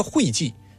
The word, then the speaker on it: 会稽: 所以你看这个会稽，现在我们念成会稽，但它本意其实都没变，可能当时也念会。